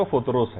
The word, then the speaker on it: فطرس: اچھا اس کو الگ الگ طریقوں سے لوگ پکارتے ہیں، لیکن اصل میں اس کا جو تلفظ ہے وہ فطرس ہے.